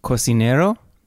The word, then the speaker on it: Cocinero: Cocinero? – I went to this place called El Cocinero. – Cocinero? – You say much better than I do. I get killed for my pronunciation.